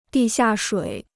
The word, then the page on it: 地下水: dì xià shuǐ